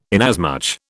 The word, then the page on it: inasmuch: British English